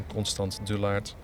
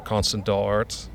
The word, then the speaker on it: Constant Dullaart: Omdat heel veel mensen in de Verenigde Staten dachten dat mijn naam, Constant Dullaert, op het Amerikaans uitgesproken Constant Dart.